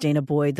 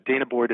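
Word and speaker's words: danah boyd: And the Dana, I think it's pronounced Dana Boyd,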